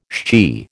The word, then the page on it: shchi: American English